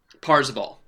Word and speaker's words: Parzival: His is what, Percival? – Parzival. Parzival is the name.